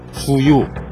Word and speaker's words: 주유: 우리가 자동차에 기름을 넣을 때도 같은 발음을 한다. 주유.